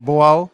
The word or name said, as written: Bual